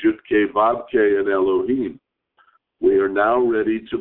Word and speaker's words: Elohim: The second Yud-Kei Vav-Kei is pronounced Elohim by the reader.